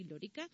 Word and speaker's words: Lorica: que nació en Santa Cruz de Lorica, ¿lo digo bien? Sí, Lorica.